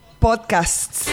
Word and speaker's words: podcasts: ¿Cómo se pronuncia? podcasts. O sea, le pones la T y al final tienes una S.